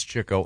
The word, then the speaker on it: Chico: It's spelled Chico, but it's Chico.